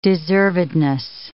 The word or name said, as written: deservedness